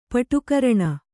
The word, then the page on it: ಪಟುಕರಣ: paṭu karaṇa; Kannada